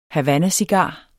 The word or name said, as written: Havannacigar